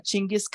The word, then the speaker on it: Chinggis: Like Chinggis or Chinggis? – Well, that's how you're supposed to pronounce Genghis. – Mm-hmm. – You got me? That's how it's supposed to be said.